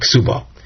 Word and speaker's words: ksuba: ksuba and kvura. There it's a kuf and a chof, but the same initial sound. With regard to both words, the way we pronounce it at least, kvura and ksuba.